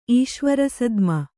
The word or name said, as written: ಈಶ್ವರಸದ್ಮ